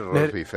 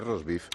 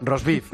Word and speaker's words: roast beef: También se sirvieron chuletas de cordero y un buffet de roast beef. Ensaladas, pescado con salmón, anchoas noruegas, ¿qué te parece? ¿Cómo vamos? Bien. Arenques. – Bien, pero no es roast beef, es roast beef. – Perdón, roast beef.